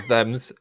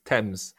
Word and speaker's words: Thames: No, it's not Thames. It's Thames.